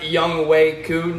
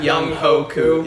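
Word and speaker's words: Younghoe Koo: His name is pronounced Young Wei Koo, not Young Ho Koo.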